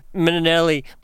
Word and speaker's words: Minnelli: She told me she gets both pronunciations. She said Minnelli, much like Liza Minnelli.